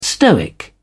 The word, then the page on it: stoic: British English